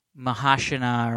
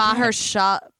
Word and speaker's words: Mahershala: Mahershala. – Mahershala.